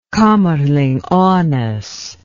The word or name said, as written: kamerlingh onnes